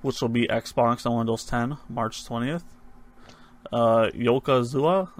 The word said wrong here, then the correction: Yakuza: did I say that right? – Yakuza 6.